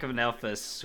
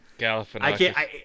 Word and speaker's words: Galifianakis: This one was so hard because Zach Galifianakis, who I know I just butchered his name. – Galifianakis.